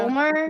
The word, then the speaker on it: fulmar: But our next animal is a bird, and it is called the northern fulmar. – Yeah, it's pronounced bird. You're right. – Thank you. Did I actually say that correctly? – Yeah, Fulmer? About right.